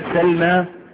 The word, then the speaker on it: سلمى: انظر إلى كلمة سلمى، سلمى الماء عليها ايه؟ الميم عليها ايه؟ فتحة.